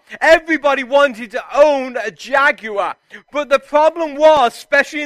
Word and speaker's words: Jaguar: This car was known as the Jaguar or as Americans butcher the English language and call it Jaguar. But actually, it's a British company, British car, so it's Jaguar.